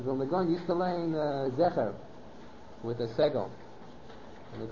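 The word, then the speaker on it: Zecher (זכר): that the word is Zecher, it's not Zohar.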